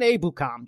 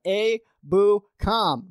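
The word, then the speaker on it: Ebukam: I think it was Samson Abukam, which, by the way, why can no commentator get that name right? It's Samson Abukam. Just say his name right. Abukam. Very simple name.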